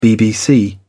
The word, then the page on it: BBC: British English